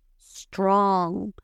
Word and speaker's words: strong: No vamos a decir strong. No le vamos a meter esa E a la S, sino que vamos a empezar con una S strong, no es strong, strong.